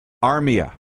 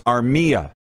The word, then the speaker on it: Armia: I said, Joel Armia. No, it's Armia. Armia. Middle syllable.